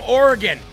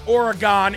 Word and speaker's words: Oregon: Terry from Oregon. I was told that it's not Oregon, it's Oregon. Jeremy, say it right.